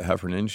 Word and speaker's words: Heffernan: And so the author, Virginia Heffernan, is that right, Heffernan? – Yeah, I think you pronounce it Heffernan.